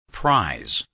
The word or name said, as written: prize